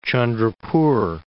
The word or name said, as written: Chandrapur